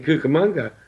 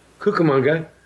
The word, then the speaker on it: Cucamonga: Prior to that phrase being popularized, there were two pronunciations of Cucamonga. One was Cucamonga, the other was Cucamonga. And it was after the Anaheim, Azusa, and Cucamonga phrase that the K, rather than the Q sound, became the common pronunciation.